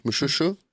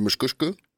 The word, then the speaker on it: Mushushu: Som jag tycker om att säga, Mushushu. – Mm-hmm. – Ibland så uttalar man det Mushkushku.